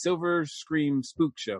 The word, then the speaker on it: Silver Scream Spookshow: And for those people who are not familiar with the I always mispronounce this. It's Silver Scream Spook Show, right? – Perfect. You did it just right.